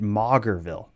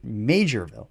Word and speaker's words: Maugerville: We had mispronounced that, and I got quite a few emails because it's spelled like M-A-U-G-H-E-R or something. So I was pronouncing it Maugerville, but they actually pronounced it Majorville.